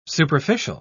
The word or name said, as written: superficial